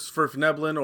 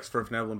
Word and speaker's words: Svirfneblin: So, deep gnomes are known as Svirfneblin, or I've heard them pronounced Svirfneblin, which I like Svirfneblin better because it sounds a little bit more, I don't know, Swedish.